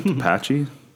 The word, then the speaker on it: Tepache: How do you pronounce that? Tepache? It's like the Apache Indians? – The Tepache Indians.